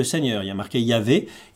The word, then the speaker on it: Yahvé: Et là, je les ai prononcées en mettant les voyelles Yahvé, de sorte à ce que ça sonne comme Yahvé.